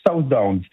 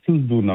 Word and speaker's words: Southdown: Les Southdown, Souzdoun en français ils disent. Les Français disent Souzdoun, mais ça s'écrit Southdown.